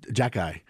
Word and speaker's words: Xhekaj: Like, he may be the best, to me, it was Arbor Jacki. His nickname is Wi-Fi because his last name is spelled X-H-E-K-A-J.